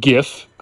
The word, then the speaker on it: GIF: Pronounced it GIF. I don't care if the creator says GIF. It stands for Graphics Interchange Format. You say the G in the graphics like a G, not like a J.